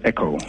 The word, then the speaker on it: Ekow: Listen, is your name EKOW? – It's pronounced EKOW.